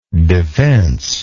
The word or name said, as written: defence